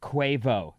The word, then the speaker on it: Quavo: Quavo actually is pronounced Quavo. Sarah was right.